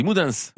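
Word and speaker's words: Moudens: C'est un nom qui se rencontre aussi sous la forme Moudin, M-O-U-D-E-N-S à la fin. – Ah ben là, on prononce tout, on dit Moudins.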